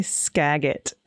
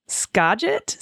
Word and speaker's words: Skagit: I almost choked on my coffee hearing AG say skagit. That lovely area is pronounced skagit? Skagit? And as to the other hilarious one, Okanagan.